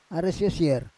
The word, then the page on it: ares yosier: Roon